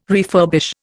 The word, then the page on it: refurbish: Indian